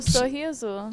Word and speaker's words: sorriso: Sorensana. – Wait, what? – Sorensana? Wait, how do you say smile? – Ah! – Oh, sorriso. – Sorriso. So, wow, I got that one way off.